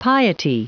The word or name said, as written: piety